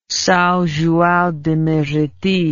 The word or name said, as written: Sao Joao de Meriti